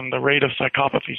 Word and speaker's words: psychopathy: Psychopathy. – Psychopathy.